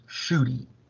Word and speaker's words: Ncuti: And this one I needed help with because his name is spelled NCUTI. And I'd love to find out how that is pronounced, shooty. Because I remember my English classes where I was told SH makes a sh sound.